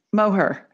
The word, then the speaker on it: Moher: Did I say that right? Moher? – Moher. Yeah.